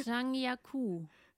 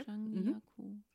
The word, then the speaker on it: Zhangjiakou: Zhangjiakou. – Ja, okay. – Zhangjiakou.